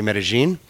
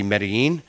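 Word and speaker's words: Medellín: So myself, when I'm in Colombia and I'm speaking Spanish and I'm dealing with locals, I say Medellin. But then when I'm talking to other travelers or people coming to visit, I usually switch back to the Medellin. Because that's more the way it's pronounced worldwide.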